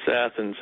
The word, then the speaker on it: Athens: So is it Athens or Athens in Georgia? – It's Athens. That is one town we do pronounce correctly.